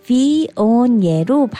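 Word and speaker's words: Viognier: 또 험드럼 비오니에를 소개해 드릴게요. 험드럼 H-U-M-D-R-U-M 따뜻한 기후의 화이트 와인으로 잘 익은 열대 과일 향이 코와 입에서 느껴지는데요. 비오니에로 발음되는 이 화이트 와인은 샤르도네와 비슷한 풍부함과 장미, 망고의 향기로운 향을 가지고 있어요.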